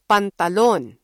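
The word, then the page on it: pantalon: Filipino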